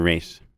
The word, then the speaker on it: Reich: Do you prefer Reich or Reich? – Reich. – Even though you spell it with an E.